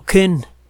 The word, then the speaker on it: Koehn: Estos investigadores jóvenes, aficionados, tienen tiempo eh, viajando a un lago que se llama el lago Ken. Se escribe K-O-E-H-N.